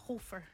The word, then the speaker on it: Hofer: What's the pronunciation? – Yeah, no, you did a pretty good job there. Hofer. – Hofer. – You can hear the phlegm in your voice when you say it.